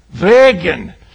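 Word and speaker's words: würgen: Did I say that right? Say, you're going to hear the authentic version. Wehrgen.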